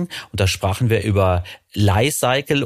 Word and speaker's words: Li-Cycle: Also die Firma heißt ganz offiziell Li-Cycle wie Life Cycle.